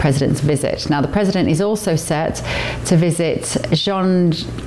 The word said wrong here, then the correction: Zhongnanhai: How do you say that? Come on, panel. – Zhongnanhai. – There we go.